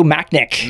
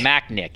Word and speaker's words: Machnik: How do I pronounce that guy's name? Ma- Dr. Joe Machnik. – Machnik, yeah. So, I mean, very smart of Fox, obviously, in, in a, in, a, VAR world to have those guys as well.